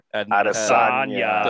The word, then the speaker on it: Adesanya: Adesanya. – The last Adesanya.